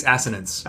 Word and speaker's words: assonance: Okay, So it's an asonis, or an asinus. – I think it's assonance. – Assonance. I didn't know how to pronounce assonance.